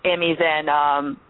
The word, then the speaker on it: Amy Zhang: Is it Amy Zhang or Zhang? – Yeah, you can just pronounce it. It's Amy Zhang.